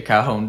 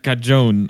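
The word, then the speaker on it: cajón: Because like, you know, how in Spanish you pronounce the J's as sort of H's. But I still don't like it because I've been calling it in Swedish a cajon for so long with like a sharp J sound.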